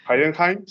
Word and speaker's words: Heidenheim: El Heidenheim. Heidenheim, sí, Y, y, y, cómo es que el Hamburgo ha celebrado un ascenso que no ha logrado, por Dios.